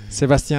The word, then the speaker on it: Sebastien: Sebastian Aguilar. Spelling would be long, but S-E-B-A-S-T-I-E-N. That's French spelling. – Sure. – Aguilar, A-G-U-I-L-A-R.